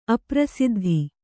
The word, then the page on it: ಅಪ್ರಸಿದ್ಧ: aprasiddha